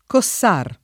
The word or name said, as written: Cossar